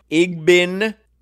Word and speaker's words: Igbinoghene: He tweeted, for those wondering, your new Dolphins cornerback is pronounced Noah Igbinoghene.